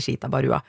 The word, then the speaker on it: Ishita Barua: Jeg var på Nyhetskompaniet på TV 2, og så skulle de introdusere meg, og programlederen sier og uttaler navnet mitt helt riktig, Ishita Barua. Men tekstingen, som er KI-generert, klarer å tekste navnet mitt til Baluba. Så i stedet for Barua, så blir det Baluba.